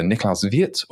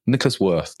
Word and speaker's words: Niklaus Wirth: So one of my favorite language designers anyway is, depends on how you pronounce the name, is either Niklaus Wirth or Nicholas Wirth, depending on if you do it by name or by value, his name, as the joke goes.